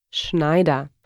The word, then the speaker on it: Schneider: Schneider.